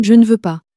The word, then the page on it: je ne veux pas: French